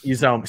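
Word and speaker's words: Uzomah: Hushman Zada, just a couple years ago. – Mispronounced his name, just completely. He went to the Jets and did nothing. – Uzoma, CJ, Uzoma.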